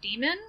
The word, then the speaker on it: daemon: what's called a demon. It's spelled D-A-E-M-O-N.